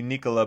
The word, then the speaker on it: Nikola: One of the things I like about Jokic, we all call him Nikola Jokic, which just sounds, it's like an American pronunciation of it, and it's supposed to be Nikola, but he doesn't care, and I respect that about him.